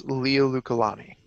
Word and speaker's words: Liliuokalani: I believe it's Liliuokalani.